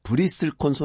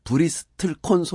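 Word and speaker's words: bristlecone: 어, 브리슬콘 소나무 이게 중간에 이제 영어로 쓰면 이제 T가 들어 있는데요. 이 네. – T자를 발음을 하기도 하고 안 하기도 해서 음. 브리슬콘 소나무라고도 하고 브리스틀콘 소나무라고도 하는 이런 나무인데요.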